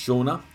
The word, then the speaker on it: Sean: This is where Sean, pronounced Shauna, has to fill gaps.